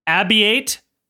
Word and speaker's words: Abbiate: I'm going to try to read it and then Abiate Buzone. – Sounds about right. – And now I'll spell it A-B-B-I-A-T-E. Abiate. – Abiate. – Abiate. – Abiate. – And then B-U-A-Z-Z-O-N-E.